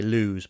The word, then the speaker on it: Lewes: It's a bit of a weird spelling. It's L-E-W-E-S. And some people say Lewes, but it is pronounced Lewes.